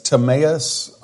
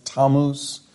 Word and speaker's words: Tammuz: some pronounce this Timaeus, others pronounce it Tammuz. I prefer to say Timaeus, but actually the correct pronunciation is Tammuz.